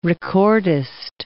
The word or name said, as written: recordist